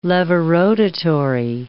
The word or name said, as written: levorotatory